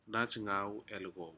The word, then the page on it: najgauelcau: Aneityum